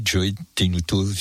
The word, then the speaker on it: Joe Tenuto: Par exemple, le Joe Tenuto Band. C'est bien comme ça qu'on dit, Jean-Philippe. Ce sera le 1er décembre, ça. – Oui, je pense qu'on doit le prononcer comme ça, oui. Joe Tenuto, c'est Tenuto Junior, parce que...